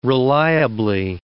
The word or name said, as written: reliably